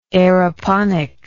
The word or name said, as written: aeroponics